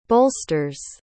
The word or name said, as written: bolsters